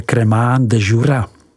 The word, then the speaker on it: Crémant de Jura: Druhou podoblastí je Crémant de Jura. Vyslovujeme Crémant de Jura.